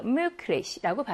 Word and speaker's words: möglich: 이 단어도 자음 L 앞에 기가 나왔으니까 Möglich라고 발음할 거예요.